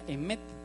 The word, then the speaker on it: emet: En hebreo verdad se, se pronuncia emet esta es la verdad.